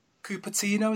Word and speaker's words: Cupertino: So we've been bouncing around a few emails today, and I know that you've been travelling backwards and forwards from Cupertino. Is that how I pronounce it? – Yeah, that's right.